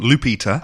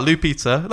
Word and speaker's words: Lupita: Lupita. – Ah, Lupita. That's like Blue Peter. Yep. – Yeah, okay. Remember for now on. Yes. Remember that it rhymes with Blue Peter.